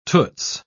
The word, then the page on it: toots: British English